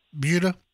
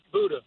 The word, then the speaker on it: Buda: Did you notice that I said that right? Buda? – You did. Most people say Buda.